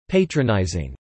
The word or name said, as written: patronising